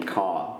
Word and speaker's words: Kerr: I think he actually pronounces his name Carr, but it is spelled K-E-R-R.